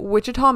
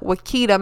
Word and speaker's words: Wichita: I kept pronouncing everything wrong. Like, Wichita Mountain. I fucking, in my head, I called it Wakita Mountain.